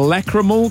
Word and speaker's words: lacrimal: Lacrimical carungles. – That is not how you say it, I'm sure. – Carunkle. – What? – A lacrimal carunkle.